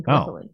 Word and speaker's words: Gallipoli: Yeah, you see Mel Gibson's butt in oh, Gallipoli.